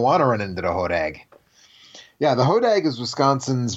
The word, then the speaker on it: Hodag: Hodag. Long A sound.